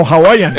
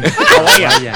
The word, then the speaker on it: Hawaiian: Hawaiian Pizza Day. – No, Hawaiian. – Hawaiian. – Al principio lo dijo bien.